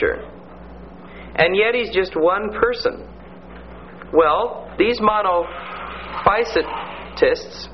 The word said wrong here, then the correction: monophysites: or they call them monophysites, is the way you pronounce it.